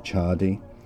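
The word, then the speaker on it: Ciardi: American poet John Chardy, I think you pronounce it Chardy, said, "There is nothing wrong with sobriety in moderation."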